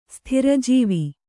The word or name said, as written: ಸ್ಥಿರಜೀವಿ